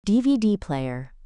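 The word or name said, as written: DVD player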